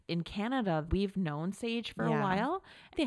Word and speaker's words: Saje: To the uninitiated, it's spelled S-A-J-E, mm-hmm, Sage Wellness. Sage is really taking off.